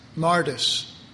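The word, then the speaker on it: Martis (μάρτυς): In the Greek, the word witness is pronounced Martis.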